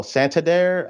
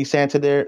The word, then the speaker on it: Santander: Uh, and so you had um, you had the first one was um, Santa Sandair. Oh, Santander. I forgot how you pronounce his name. Um, Anthony Santander.